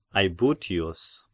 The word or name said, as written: Aebutius